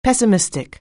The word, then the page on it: pessimistic: anglais